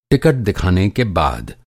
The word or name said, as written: टिकट